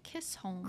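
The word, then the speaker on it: Chisholm: Chris Holm. There's no R. Kiss Holm. – Chris Holm. – Well, my cousin's name is Kronholm, and it's spelled very similarly. oh, interesting. Wait, okay. Cr- oh, Kissholm. Kissholm. You're right. There is no R.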